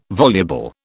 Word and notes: voluble: British English

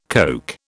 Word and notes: Coke: British English

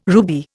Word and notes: ruby: Indian

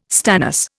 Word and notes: stannous: Indian

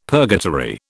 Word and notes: purgatory: British English

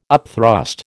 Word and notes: upthrust: American English

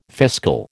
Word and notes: fiscal: American English

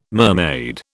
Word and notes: mermaid: British English

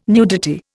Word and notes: nudity: Indian

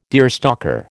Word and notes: deerstalker: American English

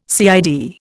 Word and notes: CID: Indian

